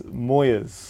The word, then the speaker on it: Moirs: Yeah, I guess it depends on who you are and where you're from, but the locals, I go from what they say, and it's Moirs.